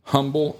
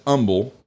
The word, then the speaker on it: humble: I'd only rate 4 stars if the Potterator didn't pronounce humble as humble. Only folks with poor English don't realize that the H is a silent sound.